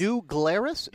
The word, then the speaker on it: New Glarus: But New Glarus, if you're ever in Wisconsin, is delicious. – New Glarus.